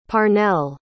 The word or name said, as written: parnell